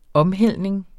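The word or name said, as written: omhældning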